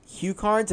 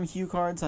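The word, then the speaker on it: HuCard: And, I mean, I always called these Hue cards. I guess most people call them Hue cards. I don't know. – Yeah, that's the correct pronunciation.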